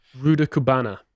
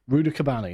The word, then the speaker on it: Rudakubana: Rudakubana. – Rudakubana, yes, traditional name.